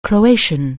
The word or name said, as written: Croatian